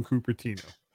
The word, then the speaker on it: Cupertino: even if they didn't come from Cupertino.